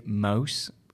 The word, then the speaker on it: Muijs: So it's spelt M-U-J-I-S, which I boggingly interpreted as Muijs and have been corrected. What's the surname? – It's a Dutch surname. So it's actually said Mulse, which is sort of a little bit like mouse, and then it's been kind of twisted in Australiana to Mulse.